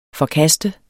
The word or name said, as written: forkaste